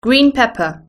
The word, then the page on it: green pepper: British English